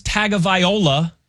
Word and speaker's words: Tagovailoa: I always thought it was Tagovailoa, but which is how it's spelled.